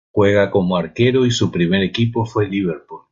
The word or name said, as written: Liverpool